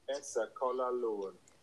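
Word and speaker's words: Eas a' Chual Aluinn: It's called Asquelinin. Asquelinin? – Really? – It's spelled E-A-S-A with an apostrophe C-H-U-A-L-A-L-U-I-N-N. Hold on. Let's see if this thing will play. – Asquelinin.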